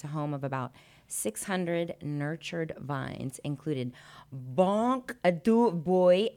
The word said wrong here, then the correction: Blanc de Bois: That is not how you say that. – Okay, how do you say it? How do you say it? – That is Blanc de Bois, Syrah, Merlot, and Caminera, New York.